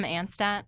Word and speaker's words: Anstadt: Anstadt.